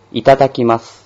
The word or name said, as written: itadakimasu